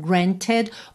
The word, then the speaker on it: granted: Então, granted, o E vem justamente fazer a ponte entre o T e o D.